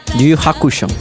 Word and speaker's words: Yu Yu Hakusho: Esta canción es el opening del, anime, Yu Yu Hachuko. ¿Lo pronuncié bien? – No, Yu Yu Hakusho.